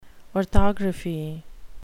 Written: orthography

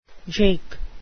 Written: jake

